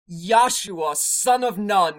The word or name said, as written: Joshua Nun